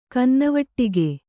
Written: ಕನ್ನವಟ್ಟಿಗೆ

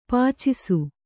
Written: ಪಾಚಿಸು

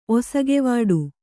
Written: ಒಸಗೆವಾಡು

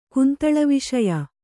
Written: ಕುಂತಳವಿಷಯ

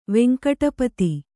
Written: ವೆಂಕಟಪತಿ

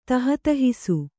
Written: ತಹತಹಿಸು

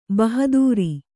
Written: ಭಾಹದರು

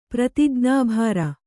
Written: ಪ್ರತಿಜ್ಞಾಭಾರ

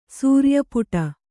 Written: ಸೂರಿಯಪುಟ

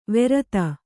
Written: ವೆರತ

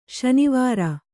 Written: ಶನಿವಾರ

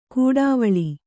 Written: ಕೂಡಾವಳಿ

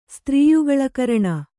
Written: ಸ್ತ್ರೀಯುಗಳಕರಣ

